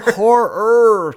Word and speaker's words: horror: You called him a whore master, and it just made me think of, like, Anchorman. Like, whore. – You're a whore master. – Horror. Two syllables.